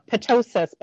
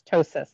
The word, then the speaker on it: ptosis: And by the way, the P in ptosis is silent. So it has some people that call it ptosis, but it's ptosis, like pneumonia, how the P is silent in pneumonia.